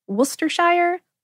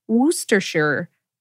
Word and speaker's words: Worcestershire: And according to the company that originally created it, Lee & Perrins, it can be pronounced Worcestershire, Worcestershire, or Worcestershire. Worcestershire sauce is used to make good things even better because it packs a lot of savoriness, sometimes called umami.